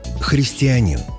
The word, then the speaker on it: христианин: Но в единственном значении мужского рода ударение падает на последний слог – «христианин».